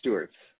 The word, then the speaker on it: Stuertz: It's Matt Stuertz.